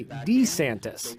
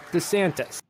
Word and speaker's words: DeSantis: He used to say DeSantis. – This is Governor Ron DeSantis. – But she persuaded him to soften the first syllable to DeSantis.